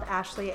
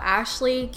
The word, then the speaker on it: Ashleigh: My name is spelled Ashleigh, A-S-H-L-E-I-G-H.